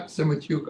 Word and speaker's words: Semotiuk: Yeah, Semichuk Andriy in Ukrainian.